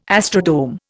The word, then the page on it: astrodome: Indian